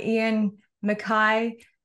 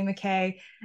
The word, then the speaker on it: MacKaye: and uh, Ian MacKaye, who I guess coined the term. I said it right. I didn't say McKay.